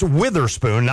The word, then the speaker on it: Witherspoon: It will be interesting to see with this collection in the backfield, with Tony Adams, a veteran, Devin Weatherspoon, still young, but yet started as a freshman or got playing time. And it's Witherspoon, not Weather. I always say that wrong.